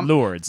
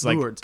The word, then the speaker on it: Lourdes: And it's this weird, Lourdes. Lourdes.